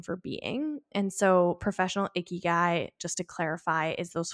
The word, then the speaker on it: ikigai: But the reason why we were talking about the Venn diagram is because we were talking about ikigai, which I did look at a video to see if I was saying it right. And it seems that that is the way it's said.